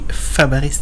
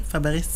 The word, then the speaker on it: Fabares: Een zekere Danny, maar ik heb niet meer verstaan van wat hem kwam, maar die gaf dat door dat het de juiste uitspraak, ik denk, als ik het goed verstaan, toch Shelley Fabares. – Fabares? – Fabares.